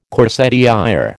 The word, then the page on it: corsetière: American English